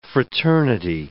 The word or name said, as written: fraternity